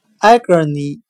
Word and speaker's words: agony: 我们用谐音法来记忆这个单词 agony，它的发音很像汉语的哀歌你，哀着的哀，切割的割，你自己的你。